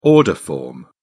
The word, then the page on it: order form: British English